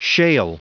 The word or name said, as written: shale